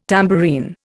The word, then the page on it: tambourine: Indian